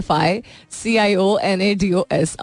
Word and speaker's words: aficionados: लिखते अफिशियोनाडोज हैं, लेकिन ये कहते अफिशियोनाडोज नहीं, अफिशियांडोज या ऐसी कुछ प्रोनाउंसिएशन होती है इसकी. इट्स विद एन AFICIONADOS.